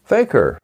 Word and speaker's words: fakir: Faker is a Muslim and Hindi-derived word, and it's pronounced about the same as is the word faker, F-A-K-E-R, in English. And I should say that the faker in Muslim is spelled F-A-K-I-R.